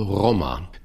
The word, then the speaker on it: Roma: Ich habe Sinti und Roma gesagt und richtig aussprechen tut man es wohl Roma. Das ist Roma, das, was im Moment, sieh mir da meine schlechte Aussprache bitte nach.